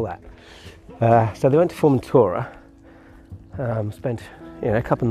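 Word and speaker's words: Formentera: They've sailed from Estepona off to Formentura. I can't say that right. Formentura, I think.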